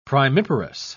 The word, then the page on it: primiparous: British English